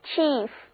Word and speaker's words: chief: 字母组合 IE 在这里发 E 的音，整个词念作 Chief。